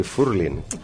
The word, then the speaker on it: furling: Después la cromatofilia, pero ahora el furling, ahora, con acento en la U.